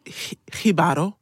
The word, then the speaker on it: Jibaro: It's called Hibaro, but it's spelled J-I-B-A-R-O.